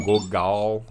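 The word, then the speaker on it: Gogol (Гоголь): O teatro completo do Gogol, Gogol, né? No, no russo mais bem pronunciado.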